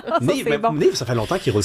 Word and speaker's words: Neev: Et non pas Neuve. – Non, ça s'écrit N-E-E-V, mais c'est très bien. Non, non, mais des fois, tu vois, c'est la liste. On va parler avec N-E-E-V. – À cause des deux E.